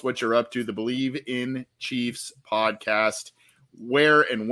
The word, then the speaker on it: Bleav: The Believe in Chiefs podcast, if you just, you know, it's a kind of an odd spelling, B-L-E-A-V, but it is pronounced Believe.